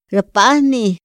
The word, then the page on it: ra pahni: Otomí